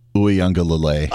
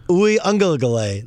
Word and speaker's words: Uiagalelei: Like, I know, how do you say his name? The quarterback of Clemson who's from St. John Bosco. – Yeah, DJ Uiagalelei. – Uiagalelei. That's Uiagalelei. That's excellent. See?